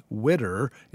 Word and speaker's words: widow: Gid Tanner played it as a comic bit, pronouncing widow as widder in order for it to rhyme with the phrase, I'll go get her.